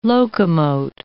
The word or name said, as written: locomote